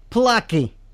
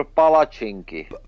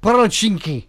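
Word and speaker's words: palačinky: you ask for plaki, right? – No, you ask for palacinki. – Oh, see, that's the problem. I didn't get the pronunciation right, but it's palacinki.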